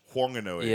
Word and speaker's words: Whanganui: Whanganui, oh, because there's the H. – Whanganui.